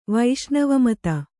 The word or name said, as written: ವೈಷ್ಣವಮತ